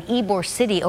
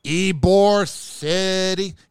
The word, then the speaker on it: Ybor City: Here's the thing with Ybor City. Here's the problem with Ybor City. You got to say it like that. You can't just say Ybor City. You got to say Ybor City.